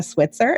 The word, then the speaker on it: Switzer: I am here with the lovely Becca Switzer. Is that pronounced right? – That's right. Okay. It's not Switzer, just like Switzerland.